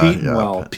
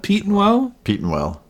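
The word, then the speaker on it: Petenwell: Well, we'll be there in Wisconsin at Lake Pentawell, or how do you say that? – Peterwell, I don't know. – Yeah. – Peetenwell. – Peetenwell. Peetenwell. There you go.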